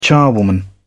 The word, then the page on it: charwoman: British English